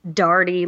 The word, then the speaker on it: Doherty: Well, because I'm Southern, I say it like Doherty, like D-A-R-D-Y, okay. But it's not like Shannon Doherty where it's an O, but it's an A, so it's more of an ah sound.